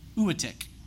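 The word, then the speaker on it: Uatchit: how do I say this? Anybody know? Uatik. There it is.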